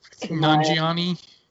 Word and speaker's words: Nanjiani: Based on Kamal Nanjiani, am I saying it right? – Nanjiani. – Nanjiani's real life, about his relationship with his American girlfriend at the time.